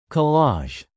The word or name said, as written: collage